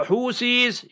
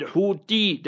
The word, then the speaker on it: Houthi: And from that side, the Yemenis and the Houthis is with a Tha. Not with a Ta. People pronounce it Houthi. That's wrong. It's Houthi. With a Tha. Alif, Be, Te, Se. Alif, Be, Te, Tha. Like that.